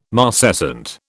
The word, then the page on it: marcescent: British English